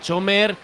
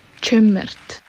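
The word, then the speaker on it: Cömert: Se dice Eray Chömert.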